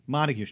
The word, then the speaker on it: Montague: be named Montague Street, unless they're like fans of Shakespeare. – Yeah, and they actually pronounce Montague Montag out in Iowa. – Montag yeah. – Street. And it's spelled M-O-N-T-A-G-U-E.